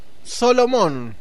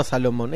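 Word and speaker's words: Solomon: Eh, bueno, así es como Salomón, Solomón nos dicen, no Salomón, esa es nuestra Nuestra ganas de pronunciarlo de ese modo.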